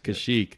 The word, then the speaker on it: Kashyyyk: Kashyyyk. Let's see if we were right. We were right, Kashyyyk.